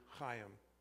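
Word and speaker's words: chayim (חיים): This life that is being talked about here in our text by the use of the Hebrew word chayim, phonetically spelled H-A-Y-I-M,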